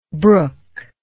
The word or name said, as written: brooklike